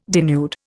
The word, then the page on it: denude: Indian